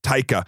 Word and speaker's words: Taika: And I've really liked, how do you pronounce his first name? – Taika.